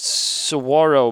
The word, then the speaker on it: Saguaro: I am sitting in the Saguaro Hotel in Phoenix, Arizona, and it's pronounced Saguaro, but it looks like Saguro.